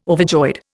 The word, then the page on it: overjoyed: Indian